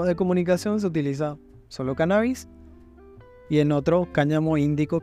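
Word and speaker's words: cánnabis: La palabra cannabis, con acento en la la primera A,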